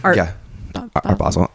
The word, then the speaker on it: Art Basel: Art Basel.